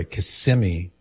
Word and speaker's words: Kissimmee: This is Dennis in Kissimmee. I've been put on notice, Cyrus. I got to say it the right way. Kissimmee. Not Kissimmee, Cyrus.